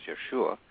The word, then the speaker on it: Yeshua: And Jesus is speaking in these pieces, or Yeshua, or how it was probably pronounced during his time as Yeshua, and speaks very, very highly and passionately about the importance of a sense of humor.